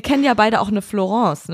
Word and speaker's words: Florence: Wird der Name auch so englisch ausgesprochen. – Ja, Florence.